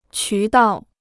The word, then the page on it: 渠道: qú dào